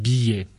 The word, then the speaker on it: billet: We don't say billet when you have double L, you will pronounce ye billet.